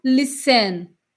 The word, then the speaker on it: listen: Eu não falo listen, eu falo listen.